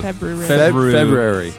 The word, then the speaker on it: February: I try to pronounce every letter. – I do too. – February.